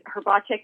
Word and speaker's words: Hrbacek: Well, I married into the Hrbacek name, and we always say that we need to buy a vowel because it's H-R-B as in boy. A-C-E-K.